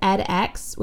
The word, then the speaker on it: edX: There's, I think it's pronounced Coursera, and then there's edX, which is ED and then X.